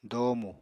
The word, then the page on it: doomu: apatani